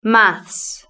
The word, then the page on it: maths: British English